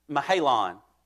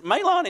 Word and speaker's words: Mahlon: And it's funny to hear people pronounce those differently. We heard one, it was like Mahalon and Chehalon or something like that. It was so weird. It's Mahlon and Chilion. All right, get it right.